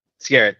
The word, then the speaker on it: Skerritt: Tom Skerritt? I don't know if I'm pronouncing that right. – Skerritt, thank you.